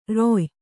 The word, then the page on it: ರಾಯಿ: rāyi